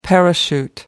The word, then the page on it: parachute: American English